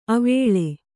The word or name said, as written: ಅವಳೆ